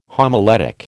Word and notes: homiletic: American English

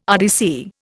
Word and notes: RDC: Indian

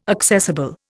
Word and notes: accessible: Indian

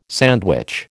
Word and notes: sandwich: American English

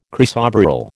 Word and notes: chrysoberyl: American English

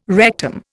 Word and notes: rectum: Indian

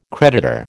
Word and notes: creditor: American English

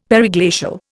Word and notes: periglacial: Indian

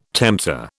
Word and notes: tempter: British English

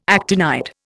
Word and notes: actinide: Indian

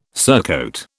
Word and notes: surcoat: British English

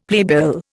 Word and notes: playbill: Indian

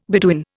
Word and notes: Beduin: Indian

